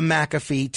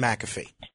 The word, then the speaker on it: McAfee: Who is or who was John McAfee? – Yeah, John McAfee was many things. You said it correctly in your intro.